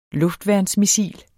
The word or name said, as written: luftværnsmissil